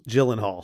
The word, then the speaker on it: Gyllenhaal: I mean, first of all, for me, just for me, the fact that Jake Glennon Hall, I guess is that how you say it? Gl- Gyllenhaal. Gyllenhaal. The fact that yep.